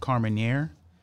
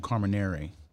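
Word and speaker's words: Carmenère: I was saying Carmenere, but it's actually Carmenere, like air.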